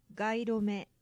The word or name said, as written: gairome